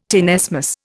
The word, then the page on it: tenesmus: Indian